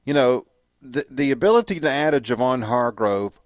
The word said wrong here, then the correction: Hargrave: for example, Hargrave, I keep on mispronouncing his name, Hargrave, or not mispronouncing, just mis-saying it.